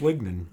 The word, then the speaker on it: lignin: Is that linen? Is that how you say it? – I think it's lignin.